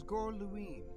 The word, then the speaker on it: Gorluin: His name was Gorluin.